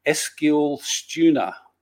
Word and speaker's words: Eskilstuna: Eskilstuna United, DFF. Is that right? Did I pronounce that close to being right in Sweden? – Very good Swedish.